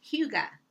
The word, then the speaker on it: Hygge: First, let me spell it for you because it's H-Y-G-G-E, but it's pronounced Hygge.